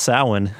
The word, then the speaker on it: Samhain: but you said happy Samhain? – What? It's actually pronounced Samhain.